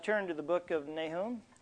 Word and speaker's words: Nahum: By the way, there's different pronunciations of this word. I've got some pronunciation software at home, and I'm pronouncing it the way that gal pronounced it on the software, Nahum. And so some say Nahum, and there's all kinds of versions, but we'll go with Nahum tonight.